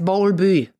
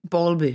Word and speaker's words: Bowlby: Ich weiß immer nicht, wie ich ihn aussprechen soll. Der heißt Bowlby. – Bowlby, genau, den hast du schon mal Bowlby erwähnt.